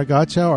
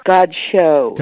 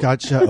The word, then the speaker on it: Godchaux: Donna Godchaux, are you there? – I certainly am, and it's Godchaux. – Godchaux, oh dear. Yeah, well, I've only been mispronouncing it for 40-plus years, so.